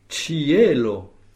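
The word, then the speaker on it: ĉielo: Et vous l'avez bien prononcé avec l'accent tonique sur le E. Cielo. Rappelons donc que le C chapeau se prononce ch.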